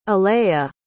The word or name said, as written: alaiya